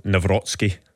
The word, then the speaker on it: Nawrocki: Yeah, I think the Polish pronunciation is Nawrocki from what I've seen, but certainly I'm sure there'll be plenty of ways it's pronounced over here.